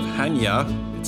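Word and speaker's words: Chania: We're about 45 minutes outside of Hania. It's spelled Kanya, but pronounced Hania.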